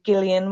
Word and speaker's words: Gillian: And he has a girlfriend named Gillian with a hard G.